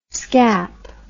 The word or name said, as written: scap